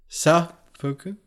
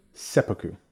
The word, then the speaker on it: seppuku: The the stress is on the first syllable. Sepuku. Sepuku. Sepuku. It's an e.